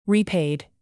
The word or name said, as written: repaid